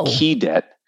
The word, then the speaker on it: keydet: Um, and the reason it's called a keydet, at least the folklore, is that that's how Southern people in the United States pronounce the word cadet, which is what you are uh, at at the Virginia Military Institute.